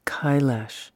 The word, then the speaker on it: Kailash: and in Savitri it's pronounced K-O-I-L-A-S, but Kailash.